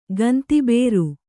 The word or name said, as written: ಗಂತಿಬೇರು